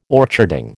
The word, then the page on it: orcharding: American English